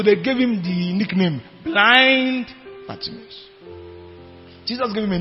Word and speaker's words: Blind: There's a footballer called Blind. It's actually pronounced Blind. It's a Dutch footballer, Danny Blind.